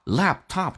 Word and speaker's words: laptop: alright, 来看一下 turn down, laptop, laptop 这个单词呢 P 不发音，两个辅音在一起只用发后面一个，第一个 P 去掉，气流阻塞一下叫 laptop, P 跟 and 连音叫 laptop,